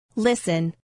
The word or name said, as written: listen